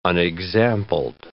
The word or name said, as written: unexampled